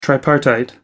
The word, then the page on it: tripartite: American English